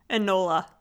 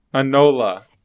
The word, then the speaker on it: Enola: Next movie is Alona? Alona? Alora? – Enola. – Enola. – It's like Enola Ebola. – Holmes. Ebola. Enola Ebola Holmes.